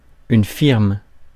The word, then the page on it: company: /ˈkʌmpəni/